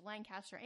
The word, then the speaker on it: Lancaster: Like, there's Lancaster, California, and there's Lancaster, England. But in Pennsylvania, if you're from there, it is pronounced Lancaster.